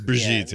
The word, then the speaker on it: Brigitte: it Brigitte? – It's Brigitte.